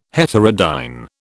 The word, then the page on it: heterodyne: British English